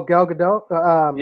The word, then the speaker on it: Gal Gadot: How do you pronounce her last name? Wonder Woman. – Oh, Gal Gadot.